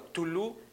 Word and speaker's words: Cthulhu: Lovecraft, que él habló o en sus libros escribió acerca de un ser llamado Cthulhu, que obviamente se escribe C-T-H-U-L-U-H, por ahí habré cometido un error, pero bueno, Cthulhu, que así se llama o así se pronuncia, es un ser que representa todo el miedo en el ser humano.